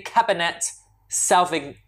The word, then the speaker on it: Cabernet Sauvignon: And lovely Cabernet Sauvignon.